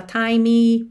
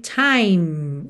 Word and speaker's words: time: Então, muito cuidado aí para você não falar timey, placey, ok? Time, o som termina no M, time.